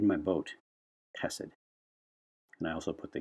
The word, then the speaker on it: chesed: It's spelled the way I spell it anyway, is C-H-E-S-E-D. chesed. Kind of like you're clearing your throat. Chesed. Kind of like that. Kind of looks like chesed or cheesed. But it's chesed.